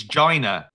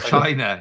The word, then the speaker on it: China: China, he pronounces like he's pronouncing vagina, doesn't he? It's Gina. It's not China. He says Gina.